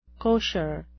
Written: kosher

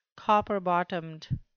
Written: copper-bottomed

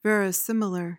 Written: verisimilar

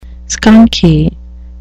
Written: skunky

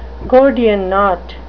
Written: gordian knot

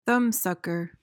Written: thumbsucker

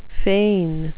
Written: fain

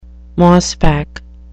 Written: mossback

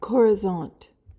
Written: chorizont

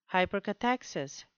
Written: hypercathexis

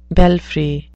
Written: belfry

